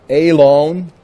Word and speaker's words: Elon: The word Elon is how it's pronounced, means oak.